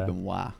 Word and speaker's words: Benoit: Is that how you say your name? Benoit? – I used to go by Benoit, but now I go by Benoit.